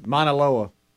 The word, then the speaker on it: Mauna Loa: M-A-U-N-A-L-O-A.